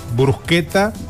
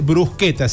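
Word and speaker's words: bruschetta: Claro, porque dice brusqueta. Si es CH, ¿cómo se dice la CH? Brucheta. – Claro, italianiza el brusqueta.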